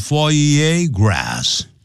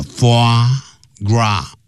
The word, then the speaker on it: foie gras: 好，这里都有几个法语啊，法文。好，一开头讲 foie gras 啊，foie gras，那不要念成 foie gras 啊，它这是固定的，它就是念成的啊，foie gras 啊，foie gras。这个鹅肝是什么东西呢？怕你不懂，所以用英文解释：is fattened，